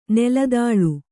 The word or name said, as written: ನೆಲದಾಳೆ